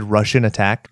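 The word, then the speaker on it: Rush'n Attack: Have you ever played Russian Attack? – I have not. – So it's spelled RUSH-N Attack.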